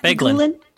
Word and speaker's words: Beguelin: Beguelin. – Beguelin. Beguelin.